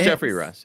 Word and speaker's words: Geoffrey: But that's spelled J-O-F-F-R-E-Y. – Yeah. – Is it? – Yeah. Huh. – Yeah, it's Jeffrey Rush. It's Jeffrey.